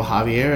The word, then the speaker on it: Javier: My full name is Gabriel Javier. I go by Gabe. It's J-A-V as in Victor, I-E-R.